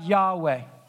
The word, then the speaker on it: Yahweh: If you look at it in your Bible, you'll see 14 times, all capital letters, L-O-R-D, Lord. That's the personal name of God, Yahweh, is how it's pronounced.